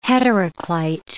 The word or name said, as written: heteroclite